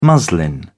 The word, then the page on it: muslin: British English